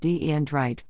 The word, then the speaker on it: Dyndrite: Seattle startup, Dyndrite, pronounced D-E-N-Drite, like the undesirable crystalline structures that form in lithium-ion batteries, has created what it thinks is a better way to define and send geometry to a 3D printer.